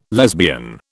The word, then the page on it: lesbian: British English